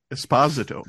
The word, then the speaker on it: Esposito: Esposito. That's how the Italians pronounce it.